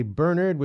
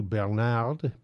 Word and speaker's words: Bernard: By the way, I say Bernard, which is the German/English pronunciation as opposed to Bernard, because my French is atrocious and I don't want to sound ridiculous.